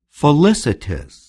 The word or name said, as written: felicitous